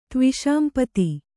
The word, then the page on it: ದೈವೀಸಂಪತ್ತು: Kannada